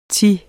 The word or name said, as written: thi